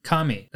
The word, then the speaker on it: kame: Today's Lessons is going to be the Japanese word for tortoise, and that is kami. That is spelled K-A-M-E, and that is very fitting for today because the first half of season 2, we follow the fight with the spirit tortoise.